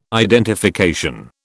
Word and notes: identification: British English

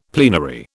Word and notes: plenary: British English

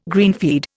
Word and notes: greenfeed: Indian

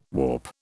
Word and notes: warp: British English

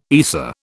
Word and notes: ESA: British English

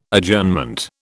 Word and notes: Adjournment: British English

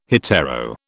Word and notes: hetero: British English

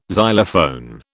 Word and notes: xylophone: British English

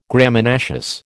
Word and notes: Graminaceous: American English

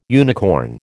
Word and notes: unicorn: American English

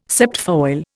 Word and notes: septfoil: Indian

